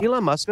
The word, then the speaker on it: Elon Musk: Elon Musk, that's, that's correct.